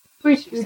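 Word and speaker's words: Quechee: So, Q-U-E, and then Chi. – Queechee? – Queechee.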